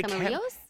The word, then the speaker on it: Camarillo: While it may have only opened in 2002, Camarillo's, and I hope I'm saying that right, Camarillo's? I don't want to say Camarillo's. – Camarillo's? Oh, okay. You're probably right.